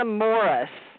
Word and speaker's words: Morris: Teresa Morris. M-O-R-R-I-S.